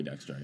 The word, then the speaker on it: Dykstra: The thing is, calling it assault makes everybody think, like, if they say Chris Hardwick sexually assaulted Chloe Dykstra, is it Chloe Dykstra? – Chloe Dykstra, yeah.